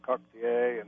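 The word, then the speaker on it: coquetier: which was pronounced in French, coctier, and then the Americans who visited the French Quarter couldn't pronounce that and mangled it, and it became cocktail.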